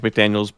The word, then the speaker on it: McDaniel: It's under Mike McDaniel's brain dead interview. I think he's on crack cocaine. You'll see it. – I also need you guys to pronounce his name correctly. There's no S.